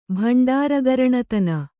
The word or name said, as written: ಭಂಡಾರಗರಣತನ